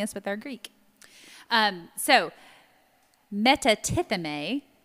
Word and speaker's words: metatithemi: So the Greek word there for deserting, it's spelled M-E-T-A-T-I-T-H-E-M-I. Do you want to give it a stab? – No. – Our resident Greek scholar is sitting in the room without a microphone. Say it loud, Calvin. – Metatithemi.